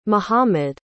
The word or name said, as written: mohamad